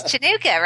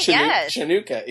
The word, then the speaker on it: Chanukah: Currently, it's potato latke eaten Hanukkah. – It's Chanukah, right? – Chanukah, exactly. Absolutely.